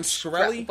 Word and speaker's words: Shkreli: Martin Shrek face lead. – Martin Shkreli? – Whatever the.